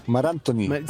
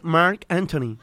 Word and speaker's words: Marc Anthony: Marc Anthony, se dice Mar Anthony. – Sí, eso. Ahí muy bien. Muy bien, muy bien. Mara, el Mar Anthony. Claro, no es Marc Anthony, es Mar Anthony.